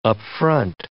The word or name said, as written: up-front